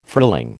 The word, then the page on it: frilling: American English